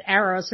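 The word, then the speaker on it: Araoz: It's pronounced Arrows, which I believe is how you pronounce one of the forms of love.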